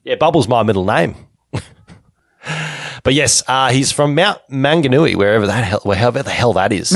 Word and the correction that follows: Mount Maunganui: You got to say it like it, Kiwi. Mount Maunganui.